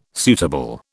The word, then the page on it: suitable: British English